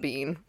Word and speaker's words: Biehn: I had to look up a pronunciation thing so I could say his last name correctly, because I was like, there's no way it's pronounced Bean, but it's pronounced Bean, pretty sure.